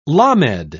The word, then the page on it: lamed: British English